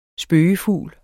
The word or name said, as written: spøge